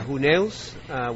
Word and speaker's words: Huneus: Huneus, which is not Spanish, would be more like of German origin, actually.